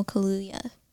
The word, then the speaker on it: Kaluuya: I think Daniel Kaluuya, I think I said his name right. – Yes.